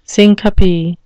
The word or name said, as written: syncope